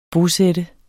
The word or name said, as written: bosat